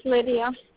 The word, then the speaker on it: Lidia: My name is Lydia. – Can you please spell your name for me? – Spell it L-I-D-I-A. – L-I-D-I-A.